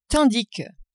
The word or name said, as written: tandis que